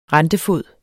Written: rentefod

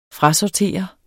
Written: frasortere

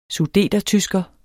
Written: sudetertysker

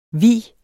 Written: hvi

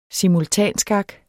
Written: simultanskak

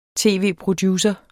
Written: tv-producer

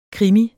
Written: krimi

